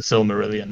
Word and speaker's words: Silmarillion: Uh, the, Sil- the Silmarillion.